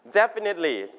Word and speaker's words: definitely: า for sure แน่นอนแต่ว่าวันนี้คุณครูมีให้อีกหลายคำเลยที่มีความหมายว่า for sure เหมือนกันคือคำว่า repeat after me definitely certainly exactly Absolutely โอเคอ่ะจดลงไปด้วยความรวดเร็วนะครับในปลายทางส่วนทั้งหมดนี้มีความหมายเดียวกันคือ for sure นะครับลงท้ายด้วย ly นะ definitely พยางค์แรก certainly